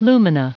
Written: lumina

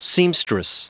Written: seamstress

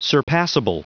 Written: surpassable

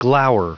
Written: glower

